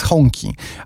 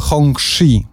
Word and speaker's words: Hongqi: I tu ruszyły rozmowy z Honki, albo Hongxi, w zależności od tego, kto wymawia. Właśnie podczas tych targów Hongqi także miało swoje stoisko i oni mówili Hongxi. Ale absolutnie każda inna osoba mówiła Honki. W każdym razie,